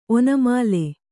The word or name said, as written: ಒನಮಾಲೆ